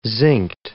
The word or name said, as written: zincked